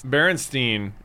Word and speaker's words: Berenstain: It's Berenstain Bears is how you say it. Berenstain. And everyone remembers it being spelled S-T-E-I-N because you say it Berenstain, but it's actually spelled Berenstain.